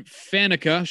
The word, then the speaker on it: Faneca: These players are guard Alan Faneca. Sure, I'm pronouncing that wrong. Nope.